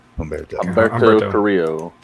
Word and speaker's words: Humberto Carrillo: Humberto Carrillo.